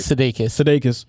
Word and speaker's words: Sudeikis: Sudeikis, yes.